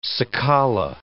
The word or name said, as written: cicala